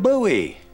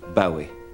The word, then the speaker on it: Bowie: Mr. David Bowie. – Thank you for having me, Bing, but I should point out it's actually pronounced Bowie. It's David Bowie.